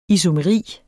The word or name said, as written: isomeri